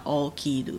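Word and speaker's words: 起きる: 嗯，嗯，好，上一段动词的话呢，我们举一个例子的话，嗯、比如说像起床的 okiru，嗯，那它写的汉字呢，就是起床的起，然后 kiru，嗯，这个起念成 o，所以叫 okiru，嗯，那如果以刚刚前面五段活用动词的那种叙述方式的话，它一定有一个语干跟语尾，对不对？嗯嗯，它的语干呢，就是。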